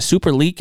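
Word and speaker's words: Superlek: You say is it Super Leak? Is that how you pronounce his name? The L E K. Yeah, uh, super he- like super league.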